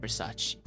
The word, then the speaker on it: Versace: But coming back to the pronunciation, how do we know we have to pronounce it Versace? – Oh, that's a good question. I don't know because when the first time I heard of Fan English, I know it's called Versace. So it's kind yeah.